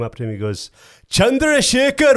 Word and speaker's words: Chandrasekhar: Chandrasekhar. – Well, you're actually pretty close. – Thank you. – In America, they say Chandrasekhar.